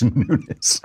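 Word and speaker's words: Nunes: So Devin Nunez or Nunez or Nunez or how do you pronounce it? That nobody knows. It's Nunez.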